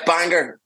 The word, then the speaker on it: Bangor: Yeah, Bangor. There's a hard G.